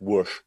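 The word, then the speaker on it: Łódź: You know, anyone who's got a, a town spelt L O D Z, which is pronounced Whoosh, you know yeah.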